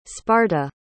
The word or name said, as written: sparta